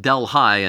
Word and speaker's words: Delhi: But it is pronounced Delhi and not Delhi. That's always confused me ever since I first moved here.